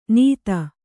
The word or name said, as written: ನೀತ